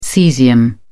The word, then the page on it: caesium: British English